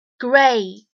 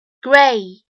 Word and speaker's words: gray: Gray, gray, gray，这个灰色当中包含了一个双元音 a，其实呢就相当于字母 a 的发音。